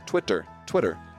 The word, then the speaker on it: Twitter: Why did I say it like that? Twitter. I pronounce the T's.